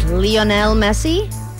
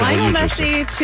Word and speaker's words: Lionel Messi: I believe the pronunciation of this name is Lionel Messi.